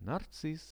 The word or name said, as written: narciss